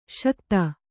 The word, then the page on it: ಸೈಕತ: Kannada